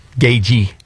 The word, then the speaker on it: Gaethje: Gaethje is how you pronounce it. The TH is silent.